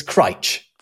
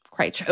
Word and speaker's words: Crich: C-R-I-C-H. It looks like Critch. But it's, I would say Crite. – Not far off. It's Critch. – Critch, okay. – Yeah. If you pronounce it any other way, you ain't from around here.